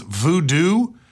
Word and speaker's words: Vodou: Because it looks like Vodou, but I believe it's Voodoo. V-O-D-O-U.